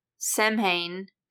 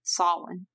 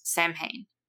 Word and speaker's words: Samhain: Sam's full name, Samhain, is pronounced as it is spelled as opposed to the Celtic holiday's pronunciation, Samhain. So we can be rest assured his name is pronounced Samhain.